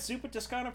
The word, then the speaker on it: Zuppa Toscana: You got some zuppa toscanas.